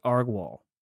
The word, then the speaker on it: Agrawal: And I think somebody at CNBC leaked that this was happening, that Parag Agro? – I heard his name pronounced Agrawal.